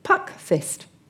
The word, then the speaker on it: puckfyst: And then we have, I'll say this carefully, puck fist. – Puck fist? – Yes, only the fist is spelt F-Y-S-T.